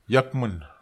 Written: iakmen

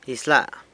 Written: Izyla.

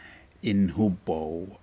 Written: inhupau

